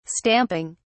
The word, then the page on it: stamping: British English